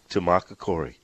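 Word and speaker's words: Tumacácori: Tamacacori.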